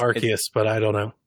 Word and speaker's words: Arceus: Is it Arceus? – It's Arceus, yes. You're correct.